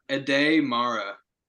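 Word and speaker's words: Aday Mara: Ade Mara. Here, I'll text it to you. It's spelled A-D-A.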